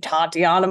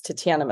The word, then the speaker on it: Tatiana: Hey, I'm Tatiana Maslany. Americans like to open up those A's in a big, beautiful vowel sound. But in Canada, or in the way it's supposed to be pronounced, it's Tatiana Maslany.